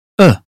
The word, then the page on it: above: British English